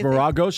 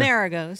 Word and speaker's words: Maragos: Oh, it's Maragos? That's how it's pronounced? Okay. – Maragos. – Oh, Colleen messed it up. I was saying Maragos all morning long.